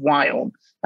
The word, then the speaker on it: Viome: So if Rob, you can help me spread the magic of Wyom. Again, that's, I can't pronounce the word we, so I always say we as in Victor, I-O-M-E.